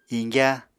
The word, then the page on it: iñgyá: apatani